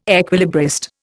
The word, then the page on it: equilibrist: Indian